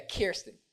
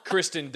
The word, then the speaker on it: Kirsten: Yeah, Kirsten. Kirsten Dunst. – It's a dick. Kristen Dunst. Kristen Dunst. – I just saying it wrong on purpose now.